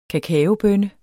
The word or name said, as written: kakaobønne